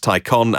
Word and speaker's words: Taycan: isn't it Taycan? And then other people have told me it should be pronounced Taycan, as in pros and cons.